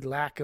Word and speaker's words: LAc: One, the abbreviation for an acupuncturist is LA, both big letters, small c, pronounced lack. So there's a joke here whenever you hear the word lack. Think L-A-C, not L-A-C-K.